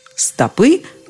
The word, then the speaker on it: стопы: Надо запомнить, что если ног больше, чем одна, то речь идет уже о стопах ног стопы, стоп, стопам, стопами о стопах. То есть ударение во множественном числе всегда на окончании, если вы хотите куда-нибудь направить свои стопы.